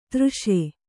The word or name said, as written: ತರ್ಷ